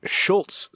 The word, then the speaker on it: Schultz: So everybody out there, go follow at Schultz Soap, which is my last name, S-C-H-U-L-T-Z, Soap.